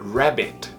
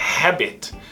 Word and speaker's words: rabbit: Preste atenção para a pronúncia do coelho. Rabbit. Não é habit. Se você fala habit, está parecendo que está falando hábito em inglês. E não um coelho. Rabbit. Esse R vai sempre ser igual do interior.